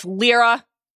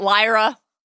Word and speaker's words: Lyra: Alarming Galen Erso, his wife Lyra, which I need to remember is pronounced that way and is not Lyra, the protagonist of His Dark Materials, and their young daughter Jin.